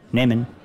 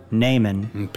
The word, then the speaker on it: nehmen: You said nehmen, which is the verb meaning to take, has a long E, and you know that because you see the silent H. So instead of short E, nehmen, we know it's nehmen.